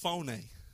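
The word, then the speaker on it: phoné (φωνή): That word is a Greek word that is pronounced phoné.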